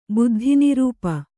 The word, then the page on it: ಬುದ್ಧಿನಿರೂಪ: Kannada